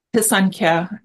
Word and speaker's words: Pisanka: You put these pictures of these beautiful, are they called Pisanki? Is that, did I say that right? – Pisanka, but I pronounce things wrong all the time.